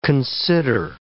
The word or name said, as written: consider